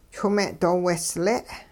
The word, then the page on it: chwing-me'doowehsle': hupa